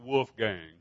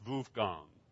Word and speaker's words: Wolfgang: And by the way, I pronounce it that way because a German girl that I knew said, it's not Wolfgang, it's Wolfgang. So for whatever that's worth there.